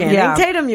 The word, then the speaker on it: Channing Tatum: Channing Tatum, you got it right.